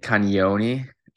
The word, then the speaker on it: Cagnoni: It's kind of like a, a Y after the first N. It's like Cagnoni.